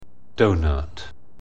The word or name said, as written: donut